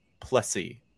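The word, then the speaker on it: du Plessis: You almost you pronounce his name like pussy, but it's Plessy.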